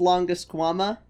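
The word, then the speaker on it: Longisquama: I think it's pronounced Longusquama.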